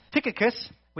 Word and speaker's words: Tychicus: To bring you up to date, Tychicus, which is how you pronounce it.